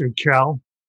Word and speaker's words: Urquell: It's pronounced Urquell. It's Urquell. But I did bring one of my favorite tequilas, Tres Generaciones.